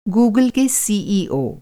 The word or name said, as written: सीईओ